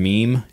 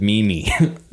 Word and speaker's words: meme: The word meme. He said meme-y.